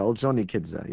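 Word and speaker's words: Ordzhonikidze: I've never known how to pronounce the name of Orjonikidze, is that right? – Yeah, Orjonikidze, yeah. – Orjonikidze, One never knows for sure where you put the stress in those.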